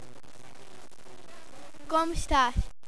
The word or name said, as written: como estas